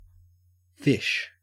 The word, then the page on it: fish: Australian English